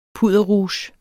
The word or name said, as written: pudderrouge